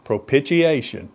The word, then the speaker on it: propitiation: Propitiation. Propitiation is how you pronounce it.